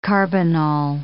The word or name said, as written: carbinol